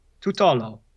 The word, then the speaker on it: Tutolo: Okay, so. Tutolo. – It's close. It's pretty good. – Yeah? It's Italian, yes. Right? – It is Italian. – Cool. Yep. Tutolo.